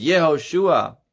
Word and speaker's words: Yehoshua: The proper pronunciation in Hebrew is Yehoshua.